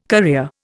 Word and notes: career: Indian

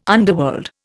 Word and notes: underworld: Indian

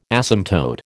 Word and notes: asymptote: American English

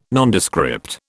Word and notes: Nondescript: British English

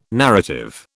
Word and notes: narrative: British English

